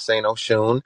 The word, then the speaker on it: St. Oshun: Um, you pronounce the name per- perfectly. It is St. Oshun.